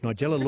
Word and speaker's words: Nigella: It is the cuisine queen herself, Nigella Lawson. Lovely work. Rhymes with Ella.